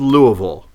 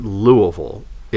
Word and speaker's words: Louisville: I said well, I'm going to Louisville to see a client, and they said oh, I love Louisville, and I thought that's not how it's pronounced. It's not Louisville. It's Louisville. But